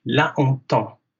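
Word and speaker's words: Lahontan: La Hontan. I asked a French interviewer, am I saying this right? And she couldn't improve on La Hontan, so we'll go with that.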